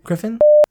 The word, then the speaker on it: Gryphon: How do you pronounce that? – Griffin? – Griffin.